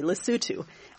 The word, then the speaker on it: Lesotho: And he mentioned one project in the tiny landlocked African country of Lesotho, which, if memory serves me, he said it correctly, Lesotho.